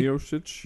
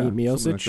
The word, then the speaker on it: Miocic: Roy Nelson, Stipe Miocic. I think I might have said that correctly. – Miocic, yeah. – Miocic. – Like that, yeah.